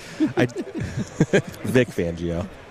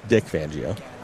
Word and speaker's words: Vic Fangio: Vic Fangio. – What did I say? – Dick Fangio.